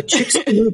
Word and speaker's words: Chicxulub: Can you talk about which species tended to survive after the Chicxulub impactor? Did I say that right? – You did. The Chicxulub asteroid.